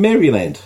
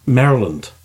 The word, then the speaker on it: Maryland: He's from Maryland. – Yeah. – That's not how they say it. – Or how do they say it? – Maryland. – Ah, right. – See, because, hello, I'm from Maryland. They seem to take objection.